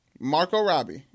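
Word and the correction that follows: Margot Robbie: It's a T silent T. Margo Robbie.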